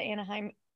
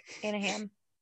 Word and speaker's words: Anaheim: I always want to say it. I know it's pronounced that because the Anaheim angels, but I'm like Anna Ham.